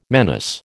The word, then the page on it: menace: American English